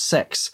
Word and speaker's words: CeX: And it's spelled C-E-X, but pronounced sex.